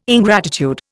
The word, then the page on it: ingratitude: Indian